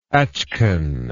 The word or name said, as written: achkan